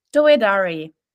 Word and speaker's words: Douedari: Douedari.